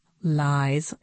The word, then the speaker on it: Lies: Hey, Lies, I said, pronounced Lies, L-I-E-S.